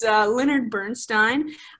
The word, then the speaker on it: Leonard Bernstein: Yeah, so the composer of the show is Leonard Bernstein, whose name I completely mispronounced in the last episode. So apologies for that, but it's Leonard Bernstein.